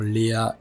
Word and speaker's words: Léa: Mais euh, perd son âme, euh, une table alors qui s'appelle Léa Coffee Table, alors Léa comme Léa en fait, qui s'écrit L-E-A.